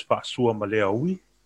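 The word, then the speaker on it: Fa'asuamaleaui: The pronunciation of that is Fasua Maleawi. – So it's got an apostrophe, doesn't it? – It's got at the beginning, but it goes Fasua Maleawi. That's the correct pronunciation.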